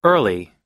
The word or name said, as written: early